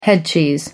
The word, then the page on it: headcheese: American English